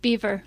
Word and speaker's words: Boever: Boever. – Okay, good. Nancy Boever, not spelled the same way as Oregon State mascot.